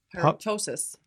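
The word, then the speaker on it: pyroptosis: Pyrotosis. Pyrotosis? Yeah. Is that P silent? – Pyro- it is. The P is silent.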